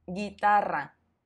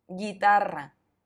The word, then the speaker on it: guitarra: Y se pronuncia gi, pero se escribe g, u, i. Por ejemplo, guitarra, guitarra.